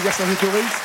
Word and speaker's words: Vercingétorix: Avec Vercingétorix. Statue équestre réalisée par Bartholdi et la statue de Dezet. Et on ne prononce pas le X à la fin.